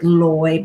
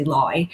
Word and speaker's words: Loi: So the word Loi, a lot of people pronounce it with Loi, but it's actually Loi.